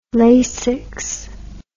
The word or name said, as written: Lasix